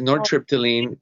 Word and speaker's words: nortriptyline: Es conocida más como nortriptyline, que es el nombre genérico. – No sabía pronunciarlo bien. – Sí, es nortriptyline, pero el Pamelor es un medicamento, tiene tiempo.